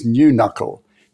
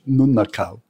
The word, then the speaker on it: Nunukul: And then the other side are Nunukal and Yugara, and you would have heard them as New Knuckle. It's pronounced or spelt N-U-N-U-K-U-L in language, Nunukal.